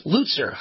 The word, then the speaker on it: Lutzer: And the pronunciation, by the way, of his last name is not Lutzer, it's Lutzer.